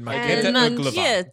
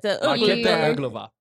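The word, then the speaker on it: Markéta Irglová: It is written by Glenn Hansen and Maketa Ergo. I fucked it. – We spent so long training you on how to say this word. Maketa Erglova. – Maketa Erglova.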